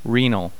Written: renal

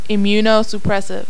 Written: immunosuppressive

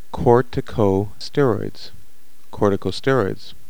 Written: corticosteroids

